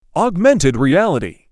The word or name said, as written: augmented reality